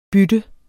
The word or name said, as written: bytte